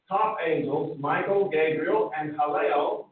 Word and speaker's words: Hallel: I've shared this many times, but in the original Hebrew, the name for Lucifer there, it should have been, in Hebrew, it's pronounced Hallel. Okay?